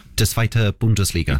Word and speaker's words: Zweite Bundesliga: Zweite Bundesliga.